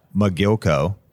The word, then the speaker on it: Mogilko: So many topics with Marina Mogilko. – Woohoo! – Did I say it right? – Thank you for having me. Yes, Silicon Valley girl.